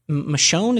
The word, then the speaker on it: Michonne: And there's still spinoffs of that, like the Michonne, is that how you say it? – Yeah.